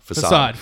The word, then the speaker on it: facade: Is that how you say it? Facade? Facade. – Facade. Facade, yeah.